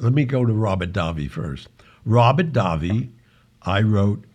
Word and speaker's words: Davi: Steve says, "Hi Gianni, did you ever meet actor and singer Robert Davi? Am I saying that right? – Oh my God, yeah.